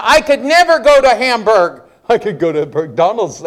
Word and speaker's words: Hamburg: Brother Sheetal, his purpose is, how do you say that? Hamburger? Hamburg? Sorry. Food, whatever. Hamburg or Hamburg? – Hamburg. – Hamburg. We're not German, we're American. Hamburg. Okay, fine, Pennsylvania.